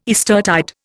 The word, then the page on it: Eastertide: Indian